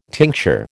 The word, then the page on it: tincture: American English